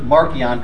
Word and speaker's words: Marcion: There was this guy called Marcion, or sometimes, I often pronounce it Marcion, because that's the way it would have been pronounced in the Latin of the time.